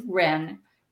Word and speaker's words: Wren: You mentioned Ren. – Right. Her name is spelled W-R-E-N.